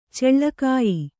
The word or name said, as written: ಚಲ್ಲಗಾಯ್